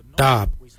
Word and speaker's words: Dhaab: Today we will look at, for knife we say darb. Darb is spelled D-H-A-A-B. It is a traditional knife, a darb.